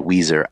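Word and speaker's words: Weiser: I was in the path in Idaho, a tiny little town called Weezer, Idaho. – Weezer, like the band? – Yeah.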